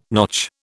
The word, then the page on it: notch: British English